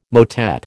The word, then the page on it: motet: American English